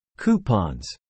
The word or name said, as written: coupons